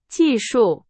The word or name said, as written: 技术